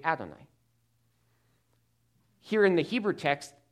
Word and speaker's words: Adonai: That is because the Hebrews would have pronounced it Adonai, Adonai, because the Hebrews did not want to pronounce God's proper name of Yahweh because it was so honored.